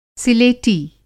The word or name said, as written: सिलेटी